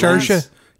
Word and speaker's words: Saoirse: Saoirse, is that how you say it? – Saoirse. Yeah, she's from Ireland, and she won.